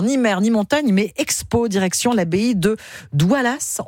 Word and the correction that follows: Daoulas: On prononce Daoulas. Le S, Daoulas.